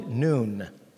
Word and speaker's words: Nun: It says in verse 1, then Joshua, son of Nun, the proper pronunciation is probably Noon, like N-O-O-N, it's pronounced Noon. You and I typically call it Nun.